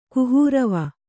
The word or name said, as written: ಕುಹೂರವ